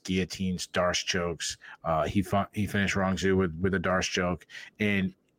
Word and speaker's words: Rong Zhu: Rong Zhu tried to take him down, and when he got him down, he swept Rong Zhu. I apologize if I'm saying his name incorrectly. – Perfect, saying it perfect.